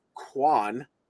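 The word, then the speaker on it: Kaun: He goes by Bishop Kwan, even though it's spelled K-A-U-N. I don't know what language that sounds like, Kwan, but anyway.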